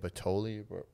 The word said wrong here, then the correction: Balotelli: Balotelli. – The Italian. – Mario Balotelli. – Balotelli, yeah.